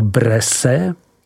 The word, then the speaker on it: Bresse: Nadmořská výška vinohradů zasahuje až do 400 metrů a nalezneme je ponejvíce kolem toku řeky Ajny v takzvané tabulové části, tedy v rovinách Bresse, vyslovuj Bres, které jsou od nepaměti známí zemědělskou činností, vinohradnictvím, ale také výrobou prvotřídního sýra Bresse Bleu, vyslovuj Bres Blu.